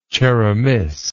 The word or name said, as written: Cheremis